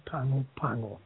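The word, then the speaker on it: Pago Pago: There's one there in a place called Pango Pango in the South Pacific, which is spelled P-A-G-O, Pago, but you always put the N in front. When you speak it in Samoan, you speak with an N in front, so it becomes Pango Pango.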